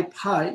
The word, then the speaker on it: phi: So why pi you know it's phi you know the f sound doesn't exist in korea right so it becomes a p sound